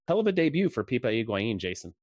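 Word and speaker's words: Higuaín: So the comeback, there were goals from Federico Higuain. Don't believe Adrian Healy when he pronounces that first name with an R in it or with two R's, I guess.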